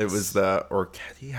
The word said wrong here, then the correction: Orecchiette: Orecchiette. – Orecchiette.